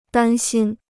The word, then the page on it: 担心: dān xīn